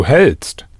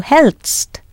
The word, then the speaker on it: hältst: How would you pronounce the du form, spelt H-A umlaut L-T-S-T? – Du hältst. – Du hältst.